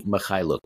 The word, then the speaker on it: Mykhailiuk: They lost uh, Kelly Oubre, Dennis Smith Jr., and Svi Mykhailiuk, I believe is how you pronounce his name.